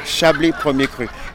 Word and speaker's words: Chablis Premier Cru: Chablis Premier Cru.